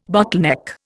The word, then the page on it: bottleneck: Indian